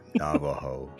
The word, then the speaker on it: Navajo: Navajo. – Navajo. Yeah, so Navajo story by a Redditor called Navajo Joe. – I doubt I'll that was it. His real Navajo name.